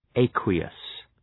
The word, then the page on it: aqueous: ‘eıkwəʋs